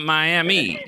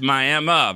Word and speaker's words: Miami: It's not Miami. It's spelled like right. Miami, but it's Miami.